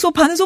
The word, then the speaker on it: 소파: 그러니까 쇼파가 아니라 소파, 철자 그대로 소파 이렇게 발음하는 게 정확하고요.